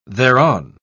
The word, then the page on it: thereon: British English